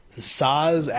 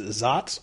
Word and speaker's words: Saaz: Now, the whole, why is Saz actually pronounced Zots? Well, it's also, Zots is a city. It's also Zotic. And the Germanic, it turns into Zots in the German translation. And it's anglified as S-A-A-Z.